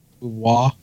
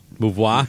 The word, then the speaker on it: Beauvoir: Do we all know how to pronounce it? Is it Beauvoir? – Beauvoir. – Beauvoir.